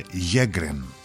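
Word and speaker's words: Sjögren: Πήρε το όνομά του, λέει, από τον Σουηδό οφθαλμίατρο Χένρικ Σέγκρεν. Στα σουηδικά προφέρεται Γέγκρεν.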